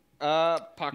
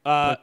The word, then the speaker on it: Pakula: Polka. Did I say his name right? – Pollock. – Pollock. What? – What? – Wasn't that Pollock? – Pakula. No. – Pakula. Yeah, Pakula.